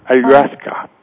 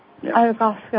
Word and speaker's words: Ayahuasca: Well, there's the one that people in South America, Ayahuasca, is that how it's pronounced? – Yes, yeah. Ayahuasca. – Ayahuasca, okay.